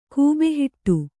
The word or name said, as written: ಗಬ್ಬುಹೊಡೆ